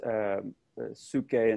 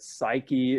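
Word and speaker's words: psyche (ψυχή): In Greek, we would pronounce these psuche and psuche a little bit differently, but psyche is what we would commonly hear in English, at least within the New Testament.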